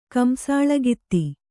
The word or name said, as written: ಕಂಸಾಳಗಿತ್ತಿ